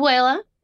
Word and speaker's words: Abuela: Is Abuela? – Mm-hmm. Abuela.